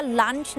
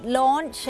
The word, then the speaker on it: launch: หลายๆคนเนี่ยมักจะอ่านว่า lunch นะคะแต่ว่าไม่ใช่ lunch นั้นมันก็คือมื้อกลางวันใช่ไหมคะอาหารกลางวันจริงๆแล้วต้องออกเสียงว่า launch นะคะนั่นก็จะเป็นสำเนียงอังกฤษ launch